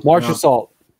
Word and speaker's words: Marchessault: Is it Mirashat? Is that what it is? – Marchessault. – Marchessault. Thank you.